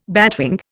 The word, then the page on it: batwing: Indian